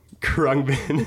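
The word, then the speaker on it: Khruangbin: At their concerts, they'll be like, hey, we're the band that you don't know how to pronounce. Yeah. It's Krungbin. Krungbin.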